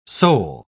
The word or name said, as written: soul